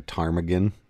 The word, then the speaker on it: ptarmigan: Like I could be like a ptarmigan. You know what a ptarmigan is? – Travis ptarmigan? No. – Ptarmigan starts with a bit of a silent P at the beginning there.